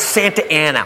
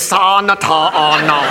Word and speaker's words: Santa Ana: It's Santa Ana. What says Santa Ana? Look, I know I didn't finish school, but that frickin' says Sa-na-ta-a-na. They pronounce it Santa Ana.